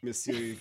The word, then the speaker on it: monsieur: Actually, how do you say sir in French? Is it Monsieur? – Monsieur Guermonfils and Alina Slitalina, who are officially engaged.